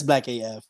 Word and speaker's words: Black AF: Is that how you say it, or do you actually say the whole thing? – No, it's Black AF.